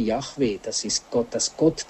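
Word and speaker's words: Jahwe: Er wird aber in der allgemeinen Überlieferung als Yahweh ausgesprochen.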